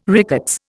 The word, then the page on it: rickets: Indian